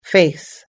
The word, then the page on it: face: American English